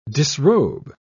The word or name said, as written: disrobe